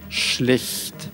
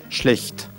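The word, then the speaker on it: schlecht: Значит, во-первых, плохой, там постарайтесь, чтобы это было с мягким хе. – Угу. – Шлехт. И эль мягкая, и хе мягкая, и вообще все мягкое. Шлехт.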